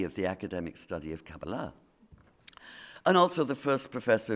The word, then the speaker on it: Kabbalah: Not least because Shabtai's messianic claim was based on the Jewish mystical tradition known as Kabbalah, or as it's pronounced here in the States, Kabbalah.